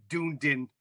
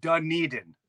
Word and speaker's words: Dunedin: Although I can see how you could say Dundon, Florida. So how do you say it? – Dunedin.